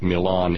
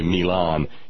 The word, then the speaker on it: Milan: Yeah, I know it's spelled like Milan, Italy, but trust me, they don't say Milan in Gibson County. It's Milan.